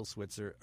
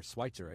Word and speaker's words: Switzer: It's kind of cool, and I really, I love that series because the two young fellas, they're now men, but Bill Switzer, or Schweitzer, I should say, is how he pronounces it,